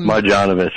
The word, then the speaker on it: Marjanovic: Boban Marjanovic.